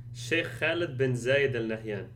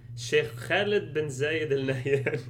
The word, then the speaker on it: Sheikh Khaled bin Zayed Al Nahyan: Now, you are going to correctly pronounce the person that he's actually selling this to. – Right, so he's selling it to Dubai-based billionaire Sheikh Khaled bin Zayed Al Nahyan. – One more time. – Sheikh Khaled bin Zayed Al Nahyan. – Right, so if you hear it pronounced other than that, it's wrong.